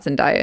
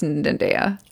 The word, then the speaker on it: Zendaya: No, she's got Zendaya, who I found out that I was pronouncing her name wrong. It's not Zendaya, it's Zendaya.